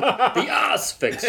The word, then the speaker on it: Asphyx: All the word for emphasis on the first syllable, the ass fix.